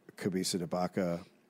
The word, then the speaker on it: Cabeza de Vaca: Some guy was like, my wife's family are Cabeza de Vaca. V and B's are pronounced the same in Spanish. And I was like, they're not.